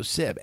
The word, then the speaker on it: Sib: My name is Joe Sib, S-I-B, people.